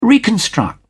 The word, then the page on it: reconstruct: British English